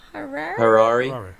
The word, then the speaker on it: Harare: Royal Harare? – Harare. Yeah. – Harare. There you go.